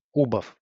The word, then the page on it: убав: ubav